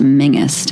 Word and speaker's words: Menzies: Tobias, his last name is actually pronounced Mingus, Tobias Mingus, but it looks like Menzies. Tobias Menzies.